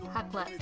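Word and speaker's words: Raclette: Rissolette. – Hatlet. – That's how you pronounce it? – The R makes a sound. Oh, there you go. – In French.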